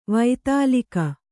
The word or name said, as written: ವಾತೂಲಿಕೆ